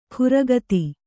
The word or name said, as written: ಖುರಗತಿ